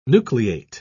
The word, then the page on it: nucleate: British English